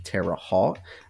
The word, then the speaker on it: Terre Haute: Terre Haute, took me all weekend to figure out how to say that properly, Terre Haute, round of applause.